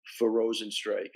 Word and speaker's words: Rozenstruik: Just it had to be a frustrating fight, you know, for Rozenstruik. I mean, am I pronouncing his name right? – You're pronouncing his name right.